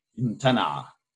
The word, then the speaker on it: امتنع: Now what's fascinating here is you can see فأمتنع, the initial vowel that's written with the alif, but you're supposed to pronounce it as a vowel, right? In classical Arabic would be امتنع. – Yeah. – فأمتنع, امتنع. There should be no فأمتنع. Should not be a hamza there, right?